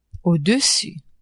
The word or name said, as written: au-dessus